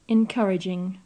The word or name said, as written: encouraging